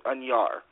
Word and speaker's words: Añar: First name, you got it right. Last name is Añar. It's got a little tilde above the N. – Okay, it's spelled A-N-A-R.